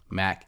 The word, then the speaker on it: Mack: My name is Chance Mack, and it's spelled how it sounds, Chance Mack, M-A-C-K.